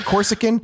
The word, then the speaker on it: Corsican: Did I say that right? Corsican? – Yeah, yeah, Corsican. Corsican's right. Okay.